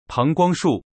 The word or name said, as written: pangguangshu